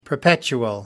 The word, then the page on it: perpetual: American English